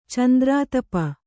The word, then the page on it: ಚಂದ್ರಾತಪ: candrātapa